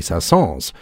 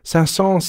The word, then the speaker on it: Saint-Saëns: It turns out that in his own era, Saint-Saëns said that his name should be pronounced like the French town of Saint-Saëns, which also has an S at the end, and at the time was not pronounced with an S. However, starting in the 40s and the 50s, that pronunciation changed. So if you were to go to Saint-Saëns now, you would hear everyone referring to it as Saint-Saëns.